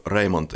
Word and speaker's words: Reymont: What is Raymond? You did it well. – Okay, thanks. Władysław Reymont is a Nobel laureate,